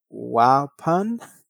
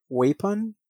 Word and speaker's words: Waupun: So on December 5th, uh, 2018, he arrived at the Dodge Correctional Institution, a maximum security prison in, I don't know if I'm pronouncing this right, but in Waupun? Waupun? It's W A U P N, Wisconsin, to serve the remainder of his life sentences.